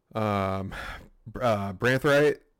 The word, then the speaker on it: Branthwaite: And Branthwaite, did I say his name right? – I think so.